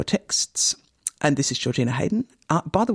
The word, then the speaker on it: Heydon: No, Hayden is a village in Norfolk, and it's spelled that way, H-E-Y-D-O-N, yeah.